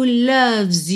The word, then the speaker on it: loves: Atenção aí a pronúncia de loves. Não é loves e nem loves, tá? Loves. O O tem som de A e o E não é pronunciado.